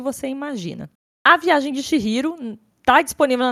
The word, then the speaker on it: Chihiro: Chihiro se escreve C H I H I R O. Então é Chihiro, só que o ri ali é com H, né, na, na pronúncia.